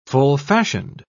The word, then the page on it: full-fashioned: British English